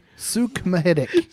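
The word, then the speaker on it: Suk Mahdik: Suck Mahidic. Is S U K is the first name, and then M A H D I K. – Suk Mahidic.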